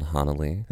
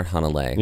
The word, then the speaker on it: Hanalei: In a land called Hanalei. – Oh, right. – Yeah. – Or Hanalei. Yeah. – We've heard both pronunciations.